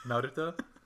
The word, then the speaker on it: Naruto: Also, did you just do the pronunciation like you did? – Naruto? What? It's right! – I'm just glad you flipped the R, that's all.